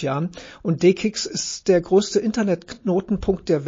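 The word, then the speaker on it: DE-CIX: Ja, da bist du bei D-Kicks gelandet. – Genau, D-Kicks, das muss man auch mal, das heißt, sie werden anders geschrieben, C-E-X, also D-E und C-I-X, aber Kicks ausgesprochen.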